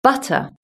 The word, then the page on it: butter: British English